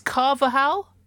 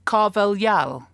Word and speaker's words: Carvalhal: Because Paul in Philadelphia, go Eagles, some of us were there, don't like to brag, says you've all been saying Carlos's name as Carvajal, but that is a Spanish pronunciation and Carvajal is Portuguese. The proper way to say his name is Carvalhal.